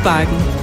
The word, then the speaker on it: Bakken: Der Nordsnakken, der shows på bakken. Nyd sommeren på bakken i verdens ældste forlystelsespark. Der Nordsnakken, der altid shows på bakken. – Bakken? Zo wordt het dus blijkbaar uitgesproken in het Deens.